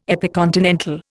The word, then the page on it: epicontinental: Indian